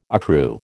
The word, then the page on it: accrue: American English